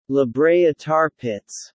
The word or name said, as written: La Brea Tar Pits